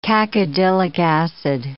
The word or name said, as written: cacodylic acid